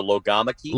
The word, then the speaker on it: logomachy: Logomachy. – Logomachy. It's spelled like logomachy, right? M-A-C-H-Y. Okay. Logomachy. It is an argument about words.